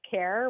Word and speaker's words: Kehr: Yeah, the artist's name I came up with is just Care, which is kind of my nickname, Karen Care, but it's spelled with my first initial and the first three letters of my last name. Yeah, right. – K-E-H-R.